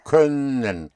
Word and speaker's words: Können: Können Sie. – Können. Können Sie. It's very important to bring out the sound of an Ö. Können. Like, think of fur, of sir. That Ö.